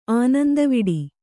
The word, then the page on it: ಆನಂದವಡೆ: ānandavaḍe